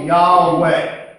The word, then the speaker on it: Yahweh: So what they did is they went through the 10 gods of the Canaanites and found one that fit in those J-H-V-H and put it in there. Now, a German, how would they pronounce a J? – Yeah. – Yeah. Okay, how would they pronounce a V? – That would be a W. – No, W. All right, here we go. Yeah, okay. So Yahweh is what they pronounce that.